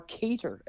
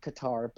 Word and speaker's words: Qatar: You've got Canada versus Qatar, or Cater, as apparently it's supposed to be called. I didn't – most people call it Qatar, but apparently the proper pronunciation is Cater, which, no, doesn't work for me, so I'm going to stick with Qatar.